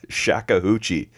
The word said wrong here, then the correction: shakuhachi: Right. I hope I'm saying that right. I should have asked him how to say that. – You said shakuhachi.